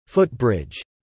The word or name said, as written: footbridge